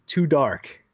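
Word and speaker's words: 2Dark: First game on the list is Too Dark, spelled or pronounced two, as in the number, and then Tark.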